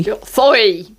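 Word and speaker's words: Zooey: de la salida de Franny Ansoway Zoe o Sui, depende de la, de la polémica, ¿cómo lo pronunciáis vosotros? Zoe o oh, Sui. – Zoe. Zoe. – Zoe.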